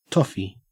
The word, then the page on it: toffy: Australian English